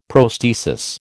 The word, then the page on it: prosthesis: American English